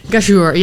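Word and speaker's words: Ghayour: Hoe zou jij dat uitspreken? – Gayhoer. – Oh ja. Gayhoer, ja.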